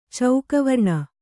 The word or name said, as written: ಚೌಕವರ್ಣ